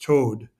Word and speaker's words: Tod: Todd Mountain in German is pronounced Toad.